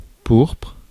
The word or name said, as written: power